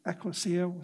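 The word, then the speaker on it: ekklesia (ἐκκλησία): It's ekklesia. Some may say ekklesia, or others may say ekklesia. Okay, so there's two different ways to pronounce it.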